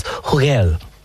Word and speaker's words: Hugel: But the lead artist is, he's French, and it's Hugel. – Hugel.